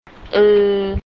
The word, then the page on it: อึ: eu; "-e + u" spoken together quickly as one diphthong sound